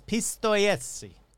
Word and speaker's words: Pistoiese: That can't be how you pronounce that. I forgot to look it up before I did that, so let's do that real quick. Pronunciation. Pistoiese. – Pistoia. – Pistoia. All right, so it's Pistoiese.